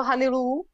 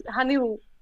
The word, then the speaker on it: Hanylou: Jak, já ti řeknu, jako, jenom jak se to vyslovuje. Jmenuje se, vyslovuje se to Hanilů. – Já, ah, promiň. – Ne, to je v pořadě, to spousta lidí to určitě neví. Hanilu. – Hanilu.